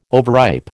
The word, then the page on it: overripe: American English